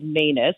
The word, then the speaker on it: Manus: My last name is Manus, which rhymes with anus.